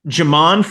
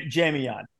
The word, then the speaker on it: Jamion: I just have my R Lads too deep up, and I'm going to talk about is it Jamon Franklin? Is that the right way to pronounce it? The D tackle for Duke? – Jamion. – Jamion, I'm sorry.